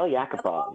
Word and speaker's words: Yakupov: But the worst part was when Tuukka decided he was going to try and stop this breakaway attempt by Yakupov. Is that how I pronounce it? – Nail Yakupov, yep.